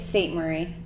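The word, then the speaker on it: Ste. Marie: It's St. Marie. – It is St. Marie. – It's French. But she's French, so I think it's just pronounced St. Marie? – Yeah.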